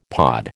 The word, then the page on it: pod: American English